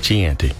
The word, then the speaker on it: Chianti: Your first movie quote mashup is: life is like a box of chocolates with some fava beans and a nice Chianti.